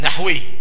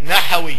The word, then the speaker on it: نحوي: بسكون الحاء، نحوي. وتخطئ العامة وتقول نحوي.